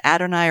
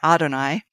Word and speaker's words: Adonai: And we were talking earlier about pronounce Adonai or Adonai. So I think either is okay, right, for the listeners? – Yes.